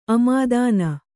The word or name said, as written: ಆಮದಾನಿ